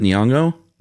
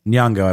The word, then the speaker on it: Nyong'o: Lupita, is it Nyong'o? – Nyong'o, I believe, yes.